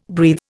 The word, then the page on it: breathe: Indian